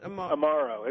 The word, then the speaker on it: Amaro: How did you say that? – Amaro. It's an Italian word.